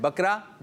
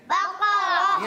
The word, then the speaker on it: بقرہ: غلط ہے کیوں؟ – غلط ہے. – بقرہ صحیح جواب کیا ہے؟ – بقرہ. – ہاں, تلفظ کی ادائیگی میں تھوڑا سا مسئلہ ہو گیا.